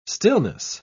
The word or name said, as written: stillness